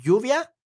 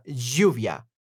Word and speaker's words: lluvia: Uh, the standard version is Lluvia, and the Argentinian version is uh, Lluvia, Lluvia, Lluvia.